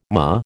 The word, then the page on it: MOH: American English